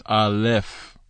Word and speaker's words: Aleph: That's why it's Aleph with the F sound at the end.